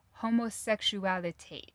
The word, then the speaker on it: Homosexualität: homosexualität. Tit. There's an umlaut over the A. Homosexualität. So, That was a German way to do it.